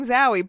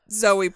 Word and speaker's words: Zowie: That May, Angie gave birth to their son, Duncan Zoe Haywood Jones, or Zoe for short. It was spelled like Bowie with a Z. – Like Zowie? – Yeah. Because I keep saying Zowie, but it was pronounced Zoe, like Bowie.